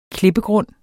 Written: klippegrund

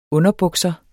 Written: underbukser

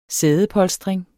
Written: sædepolstring

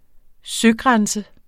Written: søgrænse

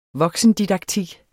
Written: voksendidaktik

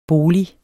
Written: bolig

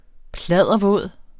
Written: pladdervåd

